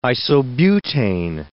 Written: isobutane

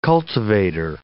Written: cultivator

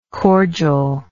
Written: cordialness

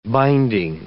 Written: binding

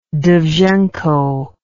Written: dovzhenko

